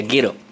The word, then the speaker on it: gyro: Que en Estados Unidos causaron confusión porque, claro, se escribe G-Y-R-O en griego y se pronuncia giro. Pero de ahí en Estados Unidos... Cuando lo escribían así, la gente decía gyro. Y también escribían en otro lado hero, como héroe, H-E-R-O.